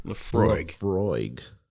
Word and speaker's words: Laphroaig: Laphroaig. – Yeah, that's what Google told me. That's how it's pronounced.